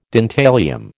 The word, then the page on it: dentalium: American English